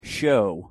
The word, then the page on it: show: English